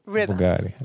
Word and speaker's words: Rimac: He pronounced it in Croatian Rimac. It's spelt Rimac.